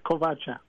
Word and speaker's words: Kovacha: No, she spelled it wrong. It's Kovacha.